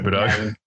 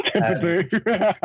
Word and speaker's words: Thibodeau: I know that because it's New York, Tom Thibodeau, whatever you pronounce it. – Thibodeau. – Yeah, that's it. Thibodeau.